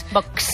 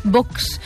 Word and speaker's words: Vox: Parece, oye, pero es de los pocos que pronuncia muy bien Vox. – Vox. – Claro, será porque es su jefe de prensa y sabe que hay que pronunciarlo con cierto empaque, ¿no? O sea, Vox.